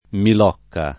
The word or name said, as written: Milocca